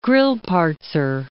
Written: Grillparzer

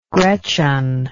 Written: gretchen

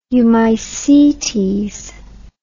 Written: eumycetes

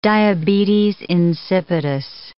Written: diabetes insipidus

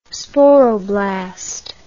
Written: sporoblast

